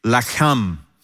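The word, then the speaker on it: lacham (לָחַם): And by the way, the word bread in Hebrew is lechem. And the word fight is lacham. Literally, from the same words, it's literally spelled the same way. Lechem, lacham. And it's just pronounced differently.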